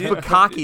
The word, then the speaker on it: bukkake: This one has buck cake. It's B-U-K-K-A-K-E. – Are you trying to say bukkake? – Is that what it is? Bukkake? – Stop playing, bro.